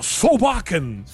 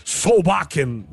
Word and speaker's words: Solbakken: Durante a nossa visita ao estádio, a coletiva do Solbakken, é assim, né, que a gente vai ter que pronunciar, Solbakken, mas é assim que a gente vai falar, mas é Solbakken, né, o técnico da Noruega.